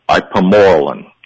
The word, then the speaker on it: Ipamorelin: Also in the formula is, I might not pronounce this too well, Carl. I have a history of mispronouncing, but Ipamorelin. – Yeah, that's it. That's good. Some people say Impormelin. That's good.